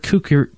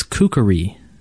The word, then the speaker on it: kukri: Uh, and uh, she explains to us that we pronounce the word kukri, kukri wrong every freaking time we say the word. It is wrong. Uh, apparently, it, it, it has three syllables, and it is not from Australia. It is from Nepal, and it is kukuri.